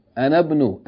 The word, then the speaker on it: أنا ابن: أنا ابن, if you join the همزة الوصل, right, you say أنا ابن. Anabnu, as though it's a short vowel. Because whenever you have a long vowel, followed by Hamza Tilwasl, you pronounce it short.